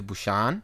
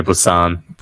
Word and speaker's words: Busan: and that is The Train to Busan. How do you say it, bro? – Train to Busan.